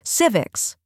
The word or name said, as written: civics